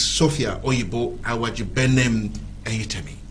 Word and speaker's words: Sophia Oyibo Awajibenem Eyitemi: I'm being joined on this segment of today's edition by no other person than Miss Sophia Oyibo Awajibenem Eyitemi.